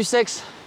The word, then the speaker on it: Scex: Après ça s'écrit pas du tout comme le sexe, ça s'écrit S-C-E-X. Ça se prononce sans le X normalement.